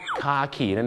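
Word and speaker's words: khaki: แต่ภาษาอังกฤษก็จะออกเสียงว่าคาขีนั่นเองครับใช่ครับคล้ายๆกับคาขีนั่นแหละฮะนะฮะ so คาขีก็คือกากีครับ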